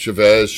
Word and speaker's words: Chavez: But America Chavez, I don't... did I say that right? – Chavez. Yeah. – Well, I thought I screwed up. – I don't know. – But no. Yeah, it's Chavez.